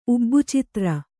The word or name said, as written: ಉಬ್ಬುಚಿತ್ರ